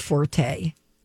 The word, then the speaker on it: forte: How to say F-O-R-T-E. – Fort. Forte.